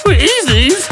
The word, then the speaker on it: Wheezy's: It's called Wheezy's. – Wheezy's. You got to say it like this. Wheezy's.